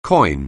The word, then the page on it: coin: anglais